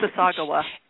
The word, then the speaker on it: Mississauga: I think it's Mississauga. Anyway, I can't say it right. – Mississauga.